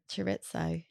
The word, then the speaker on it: chorizo: Chorizo.